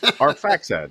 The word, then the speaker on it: Arphaxad: I hope I got close with the pronunciations. – Arphaxad, yeah, that's okay. As close you're going to get in English.